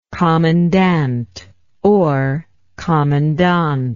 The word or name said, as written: commandantship